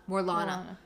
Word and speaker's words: Morlana: And then back on Morala, one Morala, How did I say it before? I can't remember. Morlana. Morlana won.